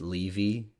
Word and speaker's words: Levy: I think it's actually pronounced Levy, that's how they pronounce it in the show, but Angstrom Levy, and he just has the ability to travel to any universe.